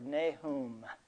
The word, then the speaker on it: Nahum: By the way, there's different pronunciations of this word. I've got some pronunciation software at home, and I'm pronouncing it the way that gal pronounced it on the software, Nahum. And so some say Nahum, and there's all kinds of versions, but we'll go with Nahum tonight.